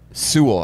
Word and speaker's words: Sewell: Panay Sewell or Sewell? – Sewell. I think you got it right.